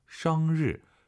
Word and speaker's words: 生日: 生日 아니고 生日 알 발음 生 벌린 상태에서 알 발음을 하려고 하세요. 生日 입을 다물지 마시고 生 벌린 상태에서 生日生日 하면 도움이 될 거예요.